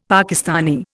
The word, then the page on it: Pakistani: Indian